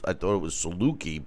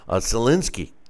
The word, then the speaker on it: Zielinski: I learned that Polish names have silent N's and S's in them, which blew my mind. I thought it was Saluki, but Zielinski is how they were pronouncing it.